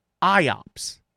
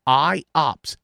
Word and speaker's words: IOPS: Something I noticed, though, a metric that's being used, it's called IOPS. That's how you pronounce it. IOPS, and it's IOPS.